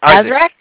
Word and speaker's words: Isaac: Hi, is Zach there? – Who? – Is Zach? I might be pronouncing it wrong. E-Z-A-C-K? – Yeah, for some reason now I can't even say it. – Isaac? Isaac? – Yes.